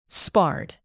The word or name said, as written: sparred